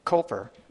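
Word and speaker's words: Cowper: And I was thinking this week of the hymn by William Cooper, is actually, I think, how you pronounce his name.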